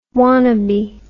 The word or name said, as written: wanabe